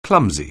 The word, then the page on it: clumsy: anglais